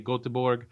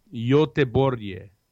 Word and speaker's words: Göteborg: So we went online and we looked up how to say the name, and I used to always say Göteborg, but it's actually, according to what we found, it's the people in Sweden pronounce it Göteborg, and I think that's pretty cool.